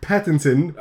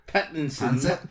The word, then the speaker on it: Pattinson: Well, he's playing Robert Pattinson. – Are you supposed to pronounce the N? Pattinson.